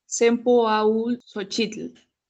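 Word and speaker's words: Cempōhualxōchitl: Su nombre proviene del náhuatl y se pronuncia Sempoaul Xochitl, que significa 20 flores o varias flores.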